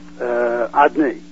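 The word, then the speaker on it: Adonai: It's written out in the four-letter form, but it's pronounced Adni. Again, I'm not using the correct pronunciation to avoid saying God's name when it's not necessary. But it's pronounced Ah, then Do, and then Noi. Those three syllables.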